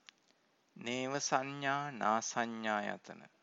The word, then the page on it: nevasannanasannayatana: Pali